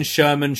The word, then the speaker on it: Sherman: Ben Sherman, Sherman rhymes with German.